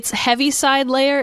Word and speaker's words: Heaviside Layer: Heavenside Lair? No, what, describe to me what this is. – So it's what it sounds like. It's Heaviside Layer is how it's pronounced. It's spelled Heaviside space Layer.